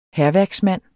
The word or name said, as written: hærværksmand